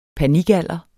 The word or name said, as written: panikalder